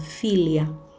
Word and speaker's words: philia: Ahora vamos a continuar hablando de otros tipos de amor y está el amor cariñoso que es el amor filia. Se escribe P-H-I-L-I-A.